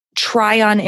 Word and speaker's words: Tryon: And Caroline, best of luck at the Tryon International Film Festival. Am I saying that right? – Yeah. Yeah, you got it.